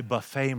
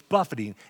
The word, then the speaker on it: buffet: Now, that can be pronounced, I buffet my body. He's not buffeting his body, like at the Golden Corral. No, he's buffeting. It means to beat it.